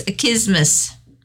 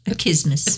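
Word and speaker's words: accismus: It's achismus. It's a Greek word, achismus.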